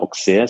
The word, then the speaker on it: Auxerre: Die Stadt Auxerre ist vielleicht jemandem nahe ein Begriff im Chablis. Die Stadt wird ja mit X ausgesprochen, Auxerre.